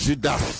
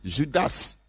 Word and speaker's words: Judas: Judas. Il faut prononcer le S, je l'ai déjà enseigné. Judas, l'Iscariote.